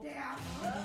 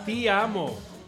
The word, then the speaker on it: Ti amo: Ti amo, ¿no? – Te amo. – Ti amo. Ti, ti, ti. – Te amo. – Ti, como si tiras así.